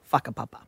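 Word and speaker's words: Whakapapa: And it's pronounced fuck-a-papa.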